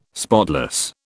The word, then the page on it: spotless: British English